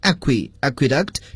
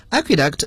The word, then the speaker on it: Aqueduct: 大家注意这个词的发音， Aqueduct 这是典型的拉丁语中的词了。 Aqueduct 在英语中表示的是一种渡槽，所谓的渡槽呢就是一种在地下挖出的这种槽，排水用的。